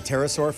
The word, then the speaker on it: pterosaur: That's a pterosaur, Fuzzy. The P is silent.